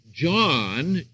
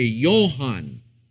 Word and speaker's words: Johann: You would not say John in German, you would say Johann, which then in the Greek it is Johannes, and so forth.